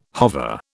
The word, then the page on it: hover: British English